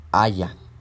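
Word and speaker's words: haya (היה): Y entonces se relaciona, e, es, este, esta, este nombre Jehová se relaciona con el verbo hebreo que más o menos se dice así, haya. Así como en español decimos haya, bueno, así también se dice en hebreo haya, pero con otro significado y aparte eh, termina con H.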